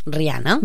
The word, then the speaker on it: Rihanna: I mira, en aquí quien dedica la cantant la Rihanna, com es pronuncia? – Rihanna.